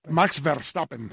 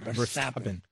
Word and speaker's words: Verstappen: ¿Cómo se pronuncia? Pepe Vance. – Max Verstappen. – Verstappen.